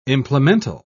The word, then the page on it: implemental: British English